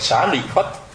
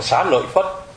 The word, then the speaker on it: Xá Lợi Phất: Đúng ra thì cái người mà ta phiên âm ra, người ta muốn mình đọc là Xá Lị Phất. Nhưng mà lúc mà mình đọc thì mình lại đọc là Xá Lợi Phất.